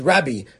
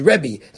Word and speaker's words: Rabbi: We spoke out that's the source, that the way to pronounce the word Rabbi is Rabbi, not Ribbi, or not even like we say, which is Rebbe. The way to pronounce it is Rabbi.